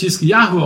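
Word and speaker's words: Hiskiyahu (חזקיהו): Hiskia wird auf Hebräisch Hiskiyahu ausgesprochen und bedeutet übersetzt, meine Stärke ist Jahwe.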